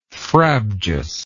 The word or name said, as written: frabjous